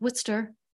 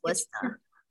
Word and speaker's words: Worcester: And yes, people, that is pronounced Worcester, even though it looks like Worcester. – It's Worcester.